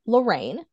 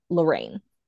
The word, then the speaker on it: Lorriane: So it's pronounced Lorraine, so you got it right, but it's spelled Lorriane.